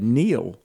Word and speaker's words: Neil: Well, so is that's not K-N-E-E-L, right? It's No. No. N-E-I-L-L.